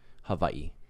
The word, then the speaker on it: Hawaii: So technically in Hawaii, like the W pronounces V. So anything you see with the W is usually V. So Hawaii is usually how you say it.